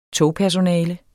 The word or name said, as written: togpersonale